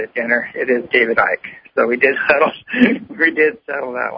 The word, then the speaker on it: Icke: And after that, after lunch, we're actually having David Icke It is Ike, right? – Yeah. – Everybody keeps Ike. – Mispronouncing that, and there we go. It's Ike, it's Ike, and everybody's arguing.